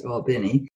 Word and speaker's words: Albany: Georgia is spelled Albany, but it's pronounced Albany.